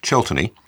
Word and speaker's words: Cheltany: My surname is pronounced Chiltenny, and it's spelled C-H-E-L-T-A-N-Y.